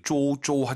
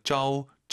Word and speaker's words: 周: 周一, 오유 발음은 어우예요. 절대 쪼쪼 하지 마세요. 쩌우 쩌이 이렇게 발음하는 거예요.